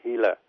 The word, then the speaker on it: Gila: Thì người ta khám phá là ở trong một cái con thằn lằn độc gọi là Hila, nó viết là G-I-L-A nhưng mà mình đọc là Hila.